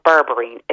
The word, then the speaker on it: berberine: The other thing that's brilliant is something called berberine.